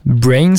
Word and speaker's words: BRNS: Brains, ça s'écrit B-R-N-S.